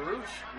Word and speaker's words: Rush: And so was his brother Roosh. Roosh was former Ring of Honor champion. Roosh. Because I used to. I'm like, why do they call him Roosh and his name is spelled Rush?